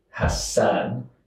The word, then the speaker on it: Hasan: So there's two different names in Arabic. One that's spelled with two S's, which is Hasan. – Yeah. – It means good doer.